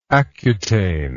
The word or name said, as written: accutane